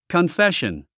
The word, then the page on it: confession: kənféʃən; カンフェション